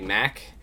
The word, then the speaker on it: Maak: and how they pronounce my name instead of Mark, they say Maak.